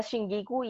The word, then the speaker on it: Shungiku: Pasemos básicamente a la terminología ya hoy, tras este breve inciso sobre eh, sobre Shungiku Nakamura. De paso, hay gente que lo pronuncia Shingiku y esa tiene más sentido, no sé por qué se me hace más sencillo.